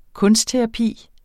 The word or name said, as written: kunstterapi